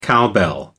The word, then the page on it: cowbell: American English